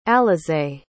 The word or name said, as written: alizeé